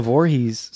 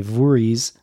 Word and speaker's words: Voorhees: And in this case, it really is kind of literal because Pamela Voorhees, Voorhees, as she pronounces it. – Voorhees. I always think it's Voorhees, but maybe it is just Voorhees. – I think we should honor the way she pronounces it from now on.